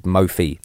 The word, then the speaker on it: Mophie: For years, I want to apologise for mispronouncing Mophie cases. – Yeah, because I didn't know that until right now, that it was called Mophie. – Yeah, we've been throwing in an extra letter somewhere and calling it something else.